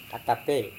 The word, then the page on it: atape: Serewen